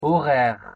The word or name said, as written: horaires